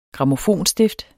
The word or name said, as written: grammofonstift